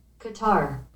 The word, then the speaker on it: Qatar: They were talking about how to pronounce gutter. Gutter, cutter, quitar. – Here's Google knows all things. Let's see. How do you say? – Qatar. – See, it's Qatar. – Yeah, but I see slow-mo. – Qatar. – Yeah, yeah, and see cutter. Google knows.